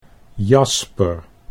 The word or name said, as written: Jasper